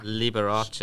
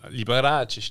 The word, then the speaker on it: Liberace: Und Liberace ist so ausgesprochen. Ja, Liberace stimmt.